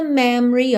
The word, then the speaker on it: memory: Atenção que esse O é praticamente mudo, tá? Você não fala memory. Memory é memory. Memory. Memory. Câmera lenta. Memory, tá? Então, memory. E a sílaba tônica é a primeira. Me. Então, depois já vai tudo diminuindo. Memory.